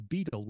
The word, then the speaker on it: betyl: But the whole concept of the beetle, which is spelled B-E-T-Y-L,